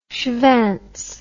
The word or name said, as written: shvantz